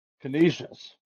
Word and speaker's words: Canisius: So we're going to go with, how do you pronounce this? Canisius? – Canisius.